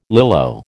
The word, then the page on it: Lilo: American English